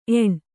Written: ಏಣ್

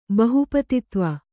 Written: ಬಹುಪತಿತ್ವ

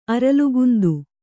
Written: ಅರಲುಗುಂದು

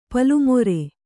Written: ಪಲ್ಲುಮೊರೆ

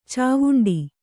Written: ಚಾವುಂಡಿ

